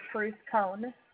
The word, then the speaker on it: Bruce Cohn: Okay, so the name is Bruce Cohn. – All right, can you spell that for me? – B-R-U-C-E-C-O-H-N.